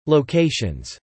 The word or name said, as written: locations